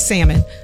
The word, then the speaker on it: salmon: And I just want to make sure you all know that the L is silent. It's salmon.